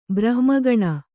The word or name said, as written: ಬ್ರಹ್ಮಗಣ